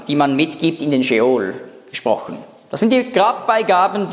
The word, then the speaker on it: Sheol: Das hebräische Wort Sheol, das kleine Strichlein dazwischen, zeigt einfach, wie man es aussprechen muss. Nicht Sheol, sondern Sheol, mit Stimmabsatz.